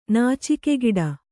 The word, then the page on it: ನಾಚಿಕೆಗೇಡಿ: nācikegēḍi